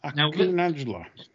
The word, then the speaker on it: Akhu Najla: A-K-H-U-N-A-J-L-A. – Aku Najla.